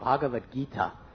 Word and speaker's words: Bhagavad Gita: I said it's called Bhagavad Gita. He said I've been reading that book for 3 years, and I never knew how to say it.